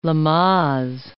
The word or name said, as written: Lamaze